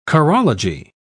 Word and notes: chorology: British English

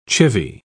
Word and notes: chivy: British English

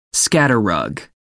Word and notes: scatter rug: American English

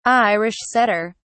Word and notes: irish setter: British English

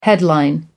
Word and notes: headline: American English

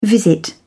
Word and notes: visit: British English

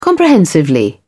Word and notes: comprehensively: British English

